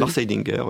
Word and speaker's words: Lars Eidinger: Enfin, au casting du film, donc il y a aussi Lars Eidinger, comment je dois prononcer ? – Lars Eidinger, d'accord.